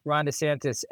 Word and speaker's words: DeSantis: And we're going to talk a little about Ron DeSantis and notice, Mark, I said his name right this time. Ron DeSantis and his endorsement of Bitcoin a little later on the show.